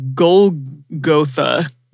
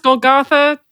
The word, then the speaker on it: Golgotha: Usually it was pronounced Golgotha. But Golgotha. Again, that's white Christian pronunciation, so who knows? – I thought growing up it was always Golgotha. – Golgotha. – Like Gargamel. – Weird. – Okay. I'll say Golgotha.